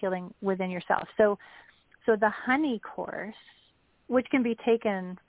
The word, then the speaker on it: Hynni: Let's talk about that, because we talked a little bit about, and it's spelled H-Y-N-N-I. – Right. – And it's pronounced honey.